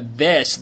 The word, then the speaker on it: this: 注意啊，在这一句话当中呢，这个 this 的发音一定要去注意。首先呢，它是一个咬舌音 this，千万不能够发成了这个 this is a book that is a desk，这样就不可以了，一定要咬舌 this。